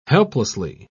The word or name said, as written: helplessly